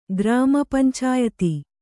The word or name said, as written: ಗ್ರಾಮಪಂಚಾಯತಿ